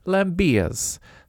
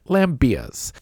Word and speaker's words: Lambiez: I don't know if I'm saying that properly or with way too much inflection, but I asked the internet how to pronounce that name, and it said Lambiez. So I'm saying it like the internet told me to say it, Lambiez.